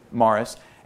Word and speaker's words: Maurice: It's kind of spelled like Maurice, but everybody says it Morris.